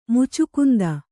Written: ಮುಚುಕುಂದ

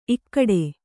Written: ಇಕ್ಕೋಡು

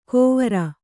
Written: ಗೊವರ

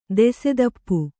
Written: ದೆಸೆದಪ್ಪು

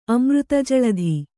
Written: ಅಮೃತಜಳಧಿ

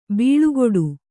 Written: ಬಾಳುಗೇಡಿ

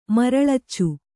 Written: ಮರಳಿಚು

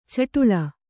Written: ಛಢಾಳ

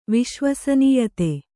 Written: ವಿಶ್ವಸನೀಯತೆ

